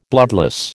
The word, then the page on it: bloodless: American English